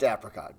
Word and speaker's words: apricot: There's two letters behind the A, so it's got to be. – Right. Makes it a short A. – A short A. If there was only one consonant. – It'd be a long A. It'd be an apricot. – If it was A-P-E-R.